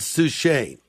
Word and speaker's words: Suchet: But there are interactive moments where you compress a little button and you hear the voice of the tremendous actor David Suchet. Am I pronouncing his name correctly, Sally? – Yes, you absolutely are.